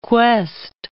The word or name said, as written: quest